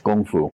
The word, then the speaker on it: gong fu: So some guys will say gong fu versus kung fu, but they're both pronounced gong fu because in Chinese, the K makes a hard sound.